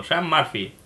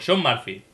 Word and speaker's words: Sean Murphy: O sea, yo digo Sean Murphy. – Y eso es bueno. – O Sean Murphy. No, o digo Sean Murphy. – Sí. – Está bien pronunciado, ¿no? – Sí.